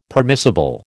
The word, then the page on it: permissible: American English